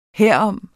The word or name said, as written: herom